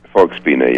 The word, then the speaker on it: Folksbiene: And this, of course, is all happening, and help me here, I think it's Volksbühne. Is that how they pronounce their performing arts center? – Volksbühne, yes.